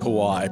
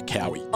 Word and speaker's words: Kauai: The island is pronounced Kauai, but I asked how to get to Kaui.